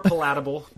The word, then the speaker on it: palatable: It was more palatable, but that pronunciation was not palatable, Sanford. – There you go. I had the wrong emphasis on the wrong syllable.